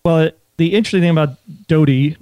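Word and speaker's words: Dodee: Well, the voice actor doing the 34 and a half hours of Under the Dome, I believe pronounces it Doty. – Doty.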